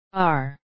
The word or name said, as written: are